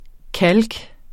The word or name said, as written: kalk